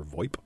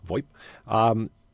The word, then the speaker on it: VoIP: The problem with internet telephony, or sometimes referred to as voice over IP or VoIP, I don't know if anybody pronounces it that way, but I tend to because it just always looked like a weird acronym to me.